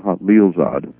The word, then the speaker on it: Khalilzad: It's spelled K-H, but the proper way to pronounce it is Khalilzad.